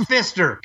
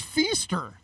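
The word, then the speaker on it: Pfister: It's pronounced Fister. Come on, say it. Does it embarrass you? You say Uranus too instead of the right name for the planet? It's German. You... It's Feaster. There's no way it's Fister.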